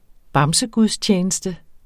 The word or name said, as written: bamsegudstjeneste